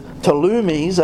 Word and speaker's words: Ptolemies: Because Daniel chapter 11, God has given a detailed account of the Ptolemies, that's how it's pronounced in the English, of Egypt, that's the king of the south, and the wars of Seleucidae of Syria, the king of the north.